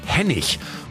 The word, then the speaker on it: Henning: Da liest man zwar Henning, aber ausgesprochen wird er Hennig.